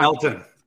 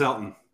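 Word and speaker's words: Elgton: How much of a difference do you think that will make if Bakhtiari and Elgin? I'm going to say that wrong. – Elton. It's just Elton. – Why? – Elton Jenkins. It's a silent G? – It's a silent G.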